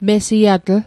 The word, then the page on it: mesiatl: Mi'gmaq